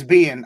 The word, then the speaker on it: Biehn: So Kyle Reese, who is played by Michael Bane, I think his name is pronounced. – Mm-hmm. Michael Biehn. – Is it Biehn? – It's Biehn. I know for sure because he was Johnny Ringo.